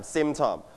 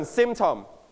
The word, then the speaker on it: symptom: Now, symptom. All right, can you listen to the audio twice? The first time. – Symptom. – One more time. – Symptom. – Your turn. Symptom. Symptom. นักเรียนปลายทางอย่าลืมออกเสียงตามคุณครูและเพื่อนๆต้นทางด้วยนะครับ Everybody, one more time. Symptom. You stress on the first syllable, symptom.